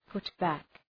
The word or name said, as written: put back